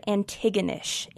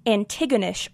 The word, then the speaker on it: Antigonish: Firstly, an internet argument about whether or not it's pronounced Antigonish or Antigonish. This is my show, and I'm more familiar with the pronunciation of the Greek play Antigone, so we're going to go with Antigonish.